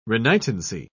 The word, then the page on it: renitency: British English